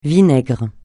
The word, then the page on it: vinaigre: francais